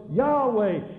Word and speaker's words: Yahweh: Yah, what does Y-A-H? That's God's name. That's God's name. Yahweh. J-H-W-H, Hebrew has no vowels, only consonants. J-H-V-H, they believe the pronunciation was Yahweh, from which we get Jehovah, from which we get translated Adonai, from which we get Lord.